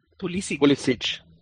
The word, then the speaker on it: Pulisic: Poliski, or what's his name? How do you pronounce it? – Pulisic.